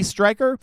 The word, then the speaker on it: Stryker: Stryker, with Ed Bouchette.